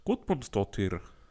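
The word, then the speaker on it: Guðmundsdóttir: Gudmundsdóttir.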